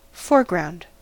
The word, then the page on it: foreground: American English